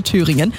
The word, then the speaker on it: Thüringen: Ray Dalton, mega Stimme, vor 2 Jahren auch live bei uns auf der Bühne bei Das Konzert von Antenne Thüringen.